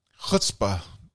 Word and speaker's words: chutzpah: But by the way, you don't pronounce the CH like the CH in church or chutney in chutzpah. No, it's more produced in the back of the throat like you're kind of sort of clearing out some phlegm.